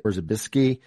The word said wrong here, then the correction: Korzybski: the general semantics. And I know that James Joyce was a huge influence on Bob. Who were some of his other major influences? – Yeah, so Korzybski, I think,